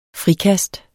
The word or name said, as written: frikast